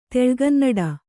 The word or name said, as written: ತೆಳುಗನ್ನಡ